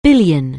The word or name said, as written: billion